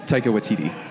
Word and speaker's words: Taika Waititi: It's, I mean, I'm just gonna butcher his name Taika Waititi. Give me it again. – If you say it real fast, people won't question it. Yeah. But it's Taika Waititi.